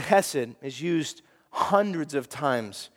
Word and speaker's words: chesed: It's the Hebrew word chesed, which you can't say correctly without hawking a little something up. Chesed is how you say it.